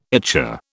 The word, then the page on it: ichor: British English